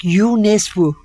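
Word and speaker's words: Jo Nesbø: Aslında isminin doğru olarak UNESCO diye okunduğu söyleniyor ama kitapların üstüne nal gibi Jo yazan birini adını Norveç'te telaffuz edildiği gibi söylemektense burada nasıl yazıyorsa öyle okuyup yanılgıya meydan vermemeyi tercih ettim.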